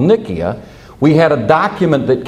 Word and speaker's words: Nicaea: And that suburb's name was Nicaea. Nicaea. Or we use it in anglicized pronunciation as Nicaea.